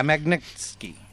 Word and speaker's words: Magnitsky: I can't even pronounce it. – Magnitsky, I think. – Yeah, Magnitsky.